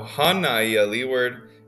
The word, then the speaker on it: Hānai: So Hānai Leeward is really important to pronounce right because it does have a kahako above the A after the H.